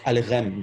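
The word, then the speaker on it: Aleichem: Euh, mais, en fait, ça se prononce Alrem. Donc, euh, de toute manière, quelle que soit la manière dont on l'écrit, il y a 9 chances sur 10 pour qu'on le prononce mal.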